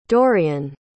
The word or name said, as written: Dorian